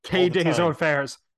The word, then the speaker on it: Quai des Orfèvres: it didn't really come up because I constantly mispronounce Croix des Orfèvres. – Quai des Orfèvres.